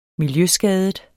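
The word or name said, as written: MILJØSKADET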